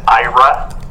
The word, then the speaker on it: AIRA: I did want to say that the it's called the IRA program, and you're right, it is A-I-R-A. They pronounce it, they pronounce it IRA, apparently.